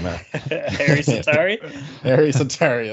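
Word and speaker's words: Harri Säteri: Yaroslav Halak and Sari Hatari, or whatever you say his name. – Harry Sitari, that's it.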